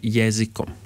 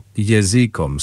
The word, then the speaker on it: jezikom: Ja mislim da je akcenat, ako kažeš jezikom, različito toga kako se akcentira ta reč, jezikom. – Razumem. Naglasak je na prvom slogu, jezikom, sa dužinom na prvom slogu i kratkim o na kraju.